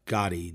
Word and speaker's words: Gadde: Gatti, I believe her name is pronounced Vijaya Gatti, G-A-D-D-E.